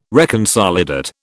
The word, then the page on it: reconsolidate: British English